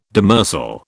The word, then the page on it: demersal: British English